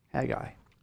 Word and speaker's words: Haggai: there's Haggai, and that's the way it's pronounced. Hag, and then I. Haggai.